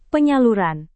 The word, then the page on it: penyaluran: indonesian